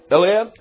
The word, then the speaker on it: Eliab: Eliab.